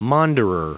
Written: maunderer

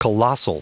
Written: colossal

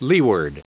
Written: leeward

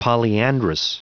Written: polyandrous

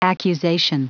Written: accusation

